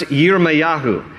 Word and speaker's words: Yirmayahu: His name in Hebrew is pronounced Yirmayahu. And Yirmayahu, scholars are, are, they debate about the actual definition for Yirmayahu.